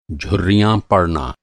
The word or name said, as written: झुर्री